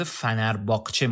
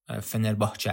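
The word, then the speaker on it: Fenerbahçe: که خب البته تو ایران با تلفظ فنرباهچه ما میشناسیمش شاید، ولی واقعا درستش فنرباهچه است.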